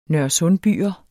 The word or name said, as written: nørresundbyer